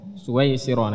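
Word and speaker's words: Suwaisiro (سويسرا): Suwaisiro, nah, diharokatin ya biar enggak salah bacanya. Suwaisiro.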